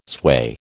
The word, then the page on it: sway: American English